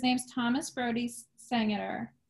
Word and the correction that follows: Sangster: Sangster. Sa- well, how do you say it? Sangster. – Sangster.